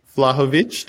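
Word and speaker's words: Vlahović: Well, speaking of Juventus, you guys have Vlahovic.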